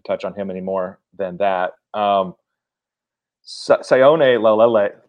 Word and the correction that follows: Laulea: I don't know how to pronounce these Polynesian last names. – Lalea. – Lalea. – L-A-U-L-E-A.